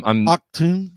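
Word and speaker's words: Achtung: Say that again. How is that pronounced? I'm Octoon. – Octoon, baby. – All right. – It means attention. – It does mean attention.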